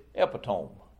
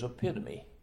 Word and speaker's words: epitome: I read that scripture that had that word in it and pronounced it epitome. After the sermon was over, she came up to me and said, "That word is epitome."